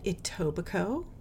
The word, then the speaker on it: Etobicoke: He grew up in Etobicoke, which is, took me a while to learn how to pronounce that because it looks, it's spelled with a C-O-K-E at the end.